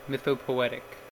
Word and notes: mythopoetic: American English

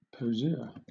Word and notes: poseur: British English

